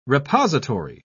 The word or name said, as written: repository